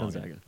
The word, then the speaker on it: Gonzaga: Gonzaga? I always say it wrong. – Gonzaga.